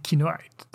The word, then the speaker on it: Kinoite: So you have two options to pronounce the name Kinoite. The first one is the English way. You say Kinoite, and it's as a reference to the blue mineral.